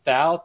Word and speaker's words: Thau: It was actually film editor Michael, I think it's pronounced Thau, T-H-A-U, to edit the footage.